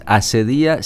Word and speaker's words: acedía: No, esta es acedía sin H, con C, acento en la I.